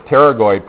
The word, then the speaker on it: pterygoid: And since you know the name of this process, the pterygoid process, we don't pronounce the P, you also know the names of those muscles, more or less.